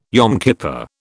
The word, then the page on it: Yom Kippur: British English